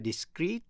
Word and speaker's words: discreet: Discreet, D-I-S-C-R-E-E-T là giữ kín. Và discreet, D-I-S-C-R-E-T-E là rời rạc. Phân tách. Phát âm như nhau, discreet.